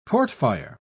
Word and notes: portfire: British English

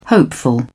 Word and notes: hopeful: British English

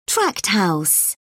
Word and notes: tract house: British English

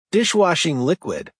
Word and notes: dishwashing liquid: American English

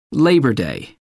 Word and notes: labor day: British English

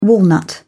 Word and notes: walnut: British English

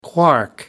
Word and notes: quark: American English

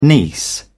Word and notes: niece: British English

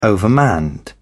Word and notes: overmanned: British English

